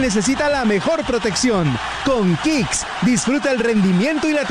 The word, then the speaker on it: Kixx: Protege tu moto con Kicks, aceites sintéticos y semisintéticos para un máximo rendimiento. Síguenos en Instagram como @aceite_kicks. Diego, ¿cómo se escribe Kicks? Ya le digo, se escribe K-I-X-X.